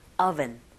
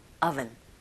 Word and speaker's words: oven: oven 하실 때도 오븐이 아니라 윗니로 아랫 입술을 살짝 긁어주셔야 되겠죠. oven, oven 갑니다.